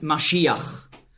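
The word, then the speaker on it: Mashiach (משיח): In meiner Bibel steht Messias, weil im Grundtext hier das Wort Mashiach, so ist die hebräische Aussprache, zu finden ist.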